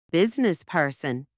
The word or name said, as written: businessperson